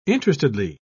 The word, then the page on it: interestedly: British English